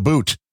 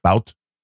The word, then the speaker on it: Bout: Well, at least you pronounce his name correctly. Unlike 99% of the media. – Then what are they calling him? – Bout. – Yeah, because B-O-U-T is the American spelling, but it's not spelled that way in Russian. It's spelled boot.